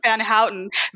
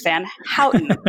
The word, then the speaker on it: Vanhouten: It's Ashley, it's Ashley Vanhouten. Vanhouten, here's how you can remember. This is Dutch and O-U-T spells out. So it's Vanhouten.